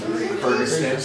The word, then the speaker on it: Kyrgyzstan: Kyrgyzstan.